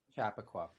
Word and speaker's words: Chappaqua: And just like had a really good time connecting, you know, is it Chappaqua? Am I saying it right? – Chappaqua. Yeah. It took me a while to get it to Chappaqua.